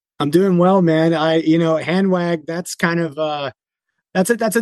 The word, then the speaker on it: Hanwag: I will say, dude, I love that you said Hanwag. That's the proper pronunciation of the brand.